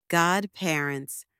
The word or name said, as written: godparents